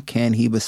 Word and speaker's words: Cannhiba: Canhiba spelled CANNHIBA, pronounced just like Cohiba, the cannabis version.